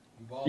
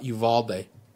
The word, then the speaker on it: Uvalde: Uribe? Am I pronouncing that right? – I believe so. Uvalde. – Yeah. Oh, what did he say? It was uh, Uvalde. Uvalde. Uvalde. Sorry, Uvalde. Those are plug and play, man.